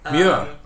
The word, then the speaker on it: Muir: Willa, it's not Moore. Moore is like frankincense of Moore. Okay. Take two. So, in the Willa and Edwin Muir translation...